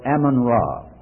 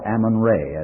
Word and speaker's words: Amun-Ra: Amun-Ra, or Amun-Re, as some pronounce it.